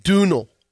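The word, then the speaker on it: Domhnall: Dunal Gleason. – Isn't that an actor? – Domino. The Irish actor that Stephen Colbert mispronounced. – It's Dunal? – I thought it was Dumnal. – Dunal. – Dunal. Dunal Gleason.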